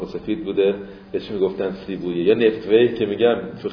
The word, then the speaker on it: سیبویه: دو تا تر الان تو شیراز میگن خیابون سیبویه، خیابونه است که قبر سیبه میگن اونجا هست، ولی تلفظ عربیش ویه بوده، سیبه ویه بوده، تلفظ فارسیش سیبویه بوده.